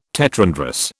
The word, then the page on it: tetrandrous: British English